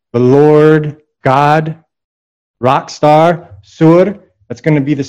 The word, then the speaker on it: Tyre: T-Y-R-E. But it's not pronounced Tyr. It's pronounced Sur, because that Sadi is like a T-S.